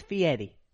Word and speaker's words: Fieri: It's Fieri. – Look up his name. – Okay. – There's no D or T in that name. It looks like Ferrari. It's Fieri. But it's pronounced with an Italian accent.